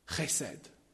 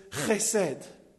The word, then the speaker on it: chesed: Right, I'm no specialist at Hebrew, but I think it's pronounced chesed.